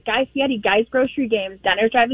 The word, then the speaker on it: Guy Fieri: I would absolutely fangirl over Guy Fieri.